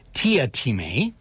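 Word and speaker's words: Teatime: And he is kidnapped by a character whose name is spelt Tea Time, but pronounced Tia Time for reasons we don't know, played in the a TV movie by Mark Warren.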